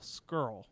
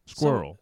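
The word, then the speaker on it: Scurll: It's S-C-U-R-L-L. Skrull. – Okay. – Skrull. – I believe you. – Skrull. Skrull. – Squirrel.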